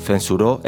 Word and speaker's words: censuró: And one more quick note, keep an ear out for the accent from Spain, where the C, which often sounds like an S sound, is instead pronounced with a th sound. So, censuró el cine sounds like censuró el cine.